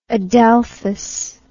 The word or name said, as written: adelphous